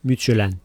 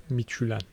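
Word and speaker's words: Midtjylland: Ne diye telaffuz ediyorum ben? – Mitchell'en. – Mitchell'en. – Buna yakın bir şey söylüyorsun. – Evet, iyi söyledin.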